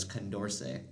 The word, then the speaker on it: Condorcet: I kept talking about, what did I say, concordant voting or something? – I think so. – Yeah, just wrong. It's not pronounced that way. It's not spelled that way. It's C-O-N-D-O-R-C-E-T, which is Condorcet.